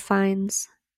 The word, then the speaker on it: Fiennes: Major Fuckface is Kroger Rafe Fiennes.